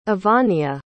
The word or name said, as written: evania